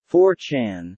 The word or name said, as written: 4chan